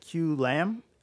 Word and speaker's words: Kieu: So the oldest victim was 88-year-old Q Lam in the city of Ottawa, our nation's capital. And Q Lam, I believe, is female. Is that correct? – Yep. – Yeah, okay. Q is spelled K-I-E-U.